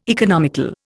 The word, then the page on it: economical: Indian